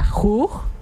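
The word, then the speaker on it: juj: Que en Yucatán se pronuncia jug. El jug.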